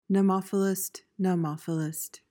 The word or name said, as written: nemophilist